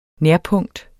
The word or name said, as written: nærpunkt